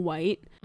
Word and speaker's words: Wight: So I, because it's spelled W-I-G-H-T. And so I'm afraid. – H-I-O. – It's spelled like right, like okay. right hand turn, but with a W.